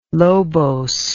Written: lobose

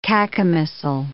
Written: cacomistle

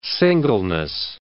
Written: singleness

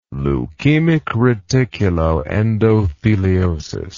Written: leukemic reticuloendotheliosis

